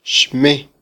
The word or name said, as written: xme